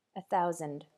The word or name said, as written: a thousand